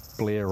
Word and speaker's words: Blair: My name Blair rhymes with Mayor, and some of my mates gee me up about that.